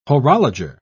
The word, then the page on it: horologer: British English